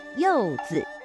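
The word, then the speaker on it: youzi (柚子): Youzi. In addition to being a season during the moon festival, the pronunciation of pomelo sounds like blessing. In Chinese, pomelo pronounced as youzi, blessing pronounced as bao you.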